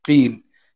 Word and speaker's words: قين: القين قاف وياء ونون قين وهو القيل، لكن هم ينطقون في في في بعض المناطق في قين.